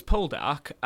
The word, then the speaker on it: Poldark: No, it's Poldark, and I've only seen it written down.